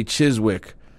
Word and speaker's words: Chiswick: Van Winkle says the W is silent, but after all these years of pronouncing the W, I can't change. So I'm going to say Chiswick. Again, right or wrong, I respond, who cares?